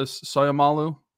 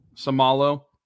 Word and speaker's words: Seumalo: but the Soyama, uh, Soyamalu is that how you pronounce Seumalo? – Seumalo, the Seumalo signing.